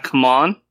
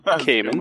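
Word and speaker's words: Camon: It sounds like how I pictured it in my head, but I've been listening to the audiobook, so I can fill in here. Cayman. – Not come on. – Not come on. Cayman.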